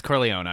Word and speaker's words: Corleone: I did it again, didn't I? Corleone? – Yeah, Corleone. Damn it. – I mean, it's Italian, so most Italian names you would pronounce them, but I'm pretty sure it's Corleone. – No, it's Corleone.